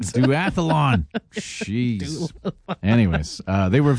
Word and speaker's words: Duathlon: I mispronounced it as the doula-thon.